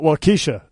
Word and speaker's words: Waukesha: Waukesha? – Waukesha.